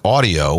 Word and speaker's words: Audi: But to set the record straight, the official pronunciation is Audi. – Oh, yeah, like, it's Audi, huh? – Like howdy, yes.